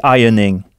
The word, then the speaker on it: ironing: The ironing. Alors, ironing s'écrit I-R-O-N-I-N-G, mais on ne prononce pas le R. Ironing.